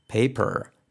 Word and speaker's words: paper: Una R británica es un poco más complicado porque no se pronuncia, pero ahí está. No sería paper, paper. Es más, tiene que más que ver con la entonación. Con lo cual, si no hacemos esa entonación, suena raro. Paper.